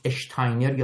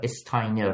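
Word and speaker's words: Steiner: مثلا اونایی که به صورت کتاب شده رو اول میگم، مثلا یک گفتگویی داشت با جورج اشتاینر یا استاینر، حالا بستگی به جای مختلفی که حالا میشه تلفظ کرد، جورج اشتاینر.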